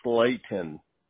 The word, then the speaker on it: Slaton: I have the spelling R-O-B-E-R-T. – Yep. – Okay, perfect. And Robert, what is your last name? – It's Slaton, and it's not it's pronounced Slayton, like there's a Slayton. – Y, like there's a Y and an E.